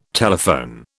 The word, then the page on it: telephone: British English